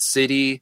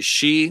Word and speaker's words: sidhe: And when I say the word she, it's spelled S-I-D-H-E. And a lot of people mispronounce it city or something like that, but it's actually she, or the longer term is ice she.